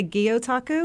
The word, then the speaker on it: Gyotaku: But before we go deep into Gyotaku, am I saying that right? – Well, we say Gyotaku.